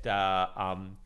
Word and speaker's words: Bernanke: That's where I met Bernanke. I always pronounce that guy's name wrong. Somebody was, you know, kind of calling me out. Yeah, it's Bernanke, right? Not Bernanke, Ben Bernanke, some way.